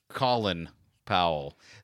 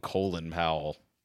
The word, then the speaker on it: Colin Powell: But it's spelled C-O-L-I-N, Colin Powell. So I've always thought that was strange. They called him Colin Powell.